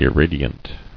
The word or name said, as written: Irradiance